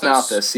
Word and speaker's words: Malthus: Thomas Malthus, yeah.